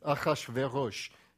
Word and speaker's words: Ahasuerus: It's Ahasuerush is the Hebrew name there.